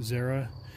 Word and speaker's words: Zara: Zara. Spelled Z-A-R-A, like Sarah, but with a Z.